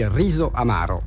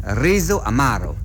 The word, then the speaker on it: Riso Amaro: Et en italien, on dit Riso Amaro. – Riso Amaro, comme ça.